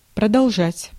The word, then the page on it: продолжать: /prədɐlˈʐatʲ/